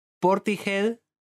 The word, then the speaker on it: Portishead: o convencidísima esta persona de que Portishead o Portishead, Portishead se pronuncia Portishead.